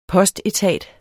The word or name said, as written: postetat